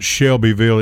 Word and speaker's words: Shelbyville: And it's not Shelbyville, it's Shelbyville, right? – Shelbyville. You can't pronounce the Y.